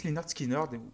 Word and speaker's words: Lynyrd Skynyrd: Donc pourquoi tu as choisi Pronounce Leonard Skinner et le groupe en particulier